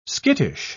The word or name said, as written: skittish